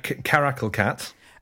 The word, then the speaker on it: caracal: The Carousel Cat? – Caracal Cat.